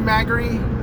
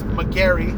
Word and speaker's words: Magary: Drew Magary. I hope I'm saying that right. I think the stress is on the first syllable. I don't think it's McGarry with the stress on the second syllable.